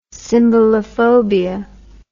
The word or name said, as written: symbolophobia